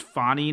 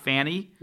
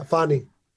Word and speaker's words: Fani: And by the way, in addition to all the other things I've learned from this conversation, I think we've learned that her name is pronounced Fannie, not Fanny. – Yeah, Fannie. – Okay. Well, good to know. – F-A-W-E.